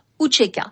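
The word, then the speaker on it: UÇK: L'armée de libération du Kosovo, qui s'écrit U-C-K. Avec un signe diacritique en dessous du C, se prononçait Ucheka.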